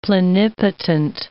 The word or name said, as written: plenipotent